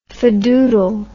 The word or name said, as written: fadoodle